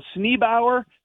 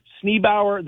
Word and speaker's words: Sneeboer: Sneebower. So it's spelled S-N-E-E-B-O-E-R, Sneebower, the Royal Dutch Hoe.